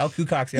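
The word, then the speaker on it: Alaqua Cox: Yep, Ala Q Cox, yep.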